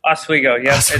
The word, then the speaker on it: Oswego: Oswego, yes. Oswego. It's definitely a Northern pronunciation. – Oswego. Okay, it's just like we.